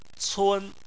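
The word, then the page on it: cun: tone 1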